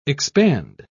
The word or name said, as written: expand